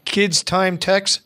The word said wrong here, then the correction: Kids in Tech: We like to confuse people. Yeah, it's kids in tech.